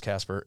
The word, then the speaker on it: Kasper: so if you know that my name is Kasper and it's spelled with a K,